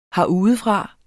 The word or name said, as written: herudefra